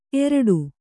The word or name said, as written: ಅಗುೞಿಸು